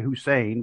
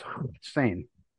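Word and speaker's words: Hossein: Of course, we always say Hussein because, we see that's the way it's spelled. And he said it was actually pronounced Hussein. He said because there is no equivalent to the sound, and that's where the HU came from.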